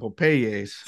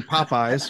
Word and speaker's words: Popeyes: So Popeyes is pronounced Popeye. – Holy shit. That's different than the Cuban impression. So our buddy mom who's Cuban, she calls it Popeyes.